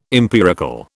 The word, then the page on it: empirical: British English